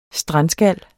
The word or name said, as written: strandskal